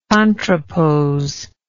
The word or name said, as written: contrapose